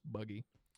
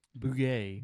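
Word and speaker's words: bugge: But that's spelled B-U-G-G-E. I don't think it's pronounced buggy. – Bugay.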